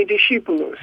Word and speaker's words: discipulus: You could say discipulus.